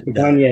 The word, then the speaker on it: Campania: It came from Campania, I believe, or Campania, I'm not sure how you pronounce that. – Campania in Naples.